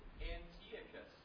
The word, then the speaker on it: Antiochus: Antiochus. That's the way it's supposed to be said.